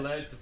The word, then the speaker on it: Vliet: Van Vliet. – Vliet. Vliet.